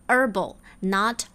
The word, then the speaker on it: herbal: 那我们听到最后这个技师给了 Net 的 solution 解决办法是 a very powerful herbal remedy。herbal 这个词它很妙，它的拼写是 H E R B A L，可是最前面的那个 H 是不发音的，所以它的发音是 herbal，not herbal，right？herbal remedy 给他开了一副中草药。并且因为是特别强效的，所以还提醒他：Now promise me you are going to use it judiciously.